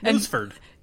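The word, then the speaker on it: Nusfjord: I don't ever know how to say that. How do you say it? – I think it's Noosefjord. – Noosefjord. – Yep. Noosefjord.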